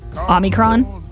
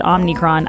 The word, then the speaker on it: Omicron: I thought I nailed Omicron, and then I started getting texts that, no, you said Omicron. I didn't. But then I got other texts saying, oh, the O in Omicron is a long O, which I guess means it would be Omicron.